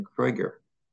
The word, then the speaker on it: Krieger: So the person who had to read the citation for the Clark Award for me was Ann Krieger, whose name is spelled like Alan Krueger's name, but she pronounces it Krieger, I think, the German way.